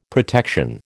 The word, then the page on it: protection: American English